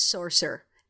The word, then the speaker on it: sorcerer: It's like, listen to how an American says it. It's sorcerer. It's two syllables. And she went, oh my God. Oh my God. I can fix this. And she went back to Benedict, and Benedict doesn't have a problem with the word anymore. Because Americans make it a two syllable word.